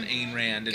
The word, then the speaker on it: Ayn Rand: A-Y-N. Is it Ayn Rand? Okay, but anyway, we're going to pronounce it Ayn Rand.